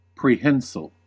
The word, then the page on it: prehensile: American English